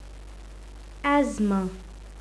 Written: asthma

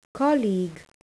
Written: colleague

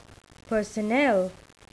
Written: personnel

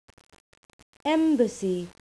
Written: embassy

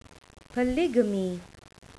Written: polygamy

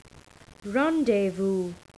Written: rendezvous

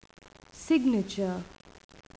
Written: signature

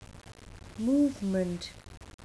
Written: movement

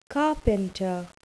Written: carpenter

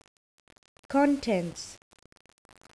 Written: contents

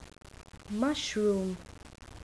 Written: mushroom

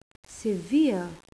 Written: severe

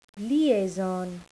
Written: liaison

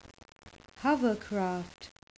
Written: hovercraft

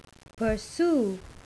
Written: pursue

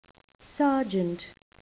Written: sergeant

